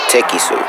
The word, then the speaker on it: TekkiiSue: Is that how you say it? – Nah, it's Tiki Sue.